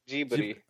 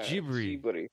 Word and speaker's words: Ghibli: It's a, based on an Italian origin. Yeah, because it's like because that, that's how it would be pronounced in Italian. It would be Ghibli, but this is Ghibli.